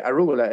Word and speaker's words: rúgula: y que rúgula ¿eso existe? ¿Se dice así? Sí, sí, sí, es así.